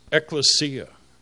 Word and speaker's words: ekklesia: Put those two together in Greek, it's pronounced ekklesia.